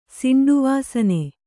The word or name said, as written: ಸಿಂಡುವಾಸನೆ